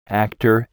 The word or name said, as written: actor